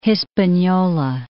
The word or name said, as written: Hispaniola